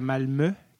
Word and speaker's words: Malmö: Il était dans le sud, à Malmö, que ça se prononce, même ça c'est s'écrit Malmo.